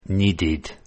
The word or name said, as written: needed